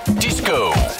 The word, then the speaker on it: disco: Og til vi igen høres ved, så husk, at disco ligesom skal udtales med et G og et lille usynligt V. Disco!